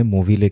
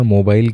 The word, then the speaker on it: Movile: मोविले केव तो मैंने जब इसके बारे में जब मैं पढ़ रहा था तो मैंने पहले इसे पढ़ा था मोबाइल केव क्योंकि स्पेलिंग में बस वी और बी का अंतर